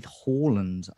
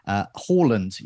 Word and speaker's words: Haaland: Now with Haaland up top, there's points tallies to look at from game week one, game week two, teams to reveal, community team latest.